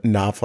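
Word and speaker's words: Knopf: They're like, it's actually noof. – It's actually a knof, like a knife, but like KN, knof.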